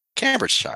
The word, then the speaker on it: Cambridgeshire: So they've obviously been getting some traction around the world, and they're even building a roughly 75,000 square foot manufacturing facility in Cambridgeshire to hopefully I pronounce that right. Cambridgeshire. – Cambridgeshire, right? – Cambridgeshire, yeah.